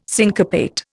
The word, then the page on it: syncopate: Indian